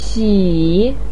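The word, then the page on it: xi: tone 3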